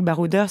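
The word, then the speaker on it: Bar2oders: Et ce qu'on a voulu construire avec Barouder, c'est que ça veut dire, du coup, nous, ça s'écrit B-A-R-2-O-D-E-R-S. E mais finalement, ça veut dire le mot B-A-R-O-U-D-E-R-S.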